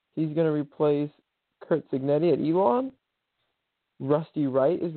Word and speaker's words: Signetti: We had, they hired their new coach, the Elon coach, Kurt, I think it's Signotti, can't say it correctly. Did I say it? It was Signetti, Kurt Signetti.